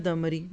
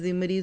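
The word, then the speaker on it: الضمري: ایک مرتبہ, اور یہ واقعہ 4 ہجری کا ہے, عمر بن امیہ دمری, میں ریکویسٹ کروں گی کہ آپ لوگ عرب لگائیں کیونکہ میں بہت سارے لوگوں کو پڑھتے بولتے غلط تلفظ دیکھتی ہوں. تو کیا ہے؟ عمر بن امیہ دمری. اب اس کو کئی طرح سے میں پڑھ لوں, عمرو بن امیہ, زمری, زمری, زمری, تو ان کو عرب سے دیکھا کرے کیونکہ یہ نام ہمارے لیے نون نہیں ہے.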